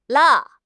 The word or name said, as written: la4